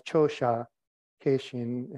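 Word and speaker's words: Chōsha Keishin: The main character is the 8th and 9th century Chinese monk Changsha Qingzhen, known as Chosha Keishin in the Japanese pronunciation.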